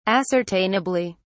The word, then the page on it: ascertainably: British English